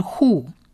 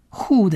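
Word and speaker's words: Who (הוא): 而第三个希伯来文字母 Aleph 不发音，所以这三个整体发起来就是 Who， Who 的音， Who， Who，他，他。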